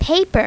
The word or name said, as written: paper